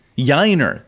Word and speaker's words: Yainer: So that tells me that they are not comfortable with playing either Corey Lee or Yainer. Pronounce that for me. I always have Yainer. – Trouble. Yainer, thank you. Yeah, yep. Like Shiner.